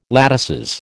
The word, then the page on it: latices: American English